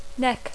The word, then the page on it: neck: English